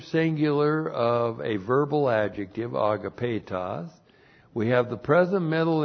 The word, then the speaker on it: agapetos: Well, we have a verbal adjective, agapetos, and we've seen this many times in our classes. A-G-A-P, long E-T-O-S, agapetos. Now, agapetos is used for the special use in the church age describing fellow members of the royal family of God.